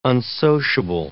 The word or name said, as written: unsociable